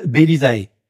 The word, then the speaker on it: Bellisae: Alors, Adeline, ta société s'appelle Belisae. Je prononce bien Oui.